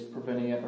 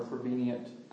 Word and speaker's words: prevenient: And the first one, I've heard it pronounced two ways, prevenient or prevenient grace, is the grace that comes to us before we even know God.